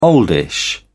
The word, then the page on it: oldish: British English